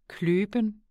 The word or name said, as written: kløben